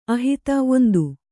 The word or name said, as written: ಆಹಿತ